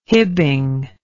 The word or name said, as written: hibbing